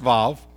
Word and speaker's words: Vav: And with a top on it, it's a Hebrew letter called Vav, or Waw, W-A-W-V-A-V, pronounced Vav.